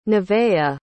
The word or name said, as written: nevaeh